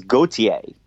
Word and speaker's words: Gauthier: How do you say his name? – I think it's Gauthier. – Gauthier. All right. Yeah, that sounds about right.